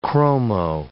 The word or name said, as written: chromo